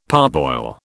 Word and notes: parboil: British English

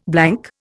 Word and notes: blank: Indian

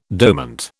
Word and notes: dormant: British English

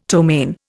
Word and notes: ptomaine: Indian

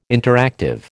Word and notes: interactive: American English

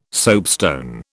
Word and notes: soapstone: British English

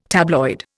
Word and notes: tabloid: Indian

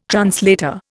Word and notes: translator: Indian

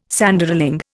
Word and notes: Sanderling: Indian

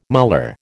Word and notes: muller: American English

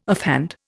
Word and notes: offhand: Indian

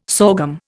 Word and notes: sorghum: Indian

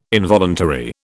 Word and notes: involuntary: British English

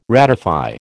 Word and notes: ratify: American English

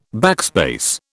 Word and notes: backspace: British English

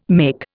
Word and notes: make: Indian

